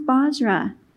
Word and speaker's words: Bozrah: It is pronounced Bozrah. We finally got it right when we interpreted it. It's pronounced Bozrah in Hebrew.